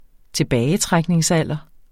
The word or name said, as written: tilbagetrækningsalder